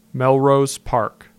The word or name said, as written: Melrose Park